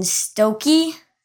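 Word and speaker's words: Stokke: Oh, and I pronounced the Maulers tight end's name Mason Stoke. It's Mason Stokey, apparently.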